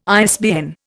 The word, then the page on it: ISBN: Indian